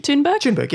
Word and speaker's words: Thunberg: No, I think you said Thunberg? – Thunberg, yeah.